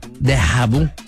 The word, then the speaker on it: déjà vu: Déjà vu.